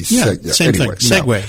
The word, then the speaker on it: segue: Now, the other thing that this how do you pronounce that? Segway. – You're like talking about the two-wheeled bicycle thing? – It sort of goes into S-E-G-U-E. – Segway. Yeah.